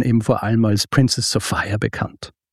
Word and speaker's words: Sophia: Geschrieben Sophia, aber entsprechend der damaligen Mode auch wird sie tatsächlich Sophia ausgesprochen.